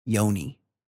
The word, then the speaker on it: yoni: Like, yani? – Yani. That's like the Indian pronunciation or something. Yoni. – Yeah, my yoni. – Y-O-N-I.